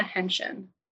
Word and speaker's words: Henschen: It's a little tricky with a silent letter in there. My name is Melissa Henschen.